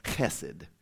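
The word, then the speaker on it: chesed (חסד): But you have one in Hebrew, and it's chesed. H-E-S-E-D. And that H is a little more of a... Although in a pandemic, you shouldn't really pronounce the gutturals.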